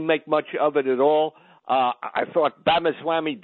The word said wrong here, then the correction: Ramaswamy: Vivek Ramaswamy.